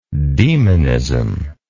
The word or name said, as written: demonism